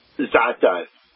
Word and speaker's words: za'atar: How do you pronounce it correctly? – Za'atar. – Za'atar. How did I pronounce it? Do you know? – Za'atar. I think you said za'atar, but I mean that, you know... That, oh, okay. – Because it's got a double A.